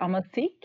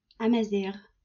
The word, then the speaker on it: Amazigh: Did I actually say that right? The Amazigh? – Amazigh. – Ah, yeah. Okay, so completely wrong.